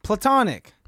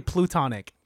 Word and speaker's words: platonic: And you say that wrong, too. – Platonic. – You say plutonic. – Plutonic. – It's you keep saying plutonic like it's a derivative of the planet Pluto, which is not a planet anymore.